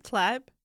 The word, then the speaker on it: Tlaib: Rashida Tlaib, and Omar. Can you say her name one more time? – Tlaib.